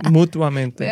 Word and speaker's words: mutuamente: no hay un L, ¿verdad? En inglés hay un L, entonces yo siempre quiero meterlo mutuamente. no, lo dije mal. – sí, es mutuamente.